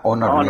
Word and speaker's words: Honor: Pues ah, yo quiero agradecerte, vicepresidente de ventas de Honor, así se pronuncia, ¿verdad? – Honor, México.